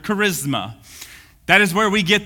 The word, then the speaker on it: charisma: This is exactly how you would pronounce it. Charisma.